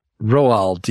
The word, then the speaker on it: Roald: Where he tells kids, Ronald Dahl, the author. – Yeah, Roald, yeah. – What? Did I say his name wrong? What is it? – Roald Dahl, not Ronald Dahl.